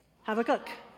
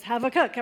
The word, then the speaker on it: Habakkuk: The Hebrew pronunciation of the word is the third one, Habakkuk. The B becomes a V, so it's Habakkuk. Everybody say Habakkuk. That's actually the Hebrew way.